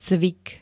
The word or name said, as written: cvik